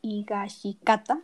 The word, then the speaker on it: Higashikata: que es una enfermedad transmitida por la familia Higashakita, no, Higashikata, lo dije mal, de generación en generación, que hace que a partir de tu cuerpo comienza a, transformarme, a transformarse en roca.